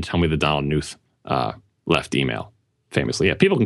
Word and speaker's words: Knuth: So anyway, Knuth is the, if I'm pronouncing his name correctly. – Knuth. – I don't like saying the K.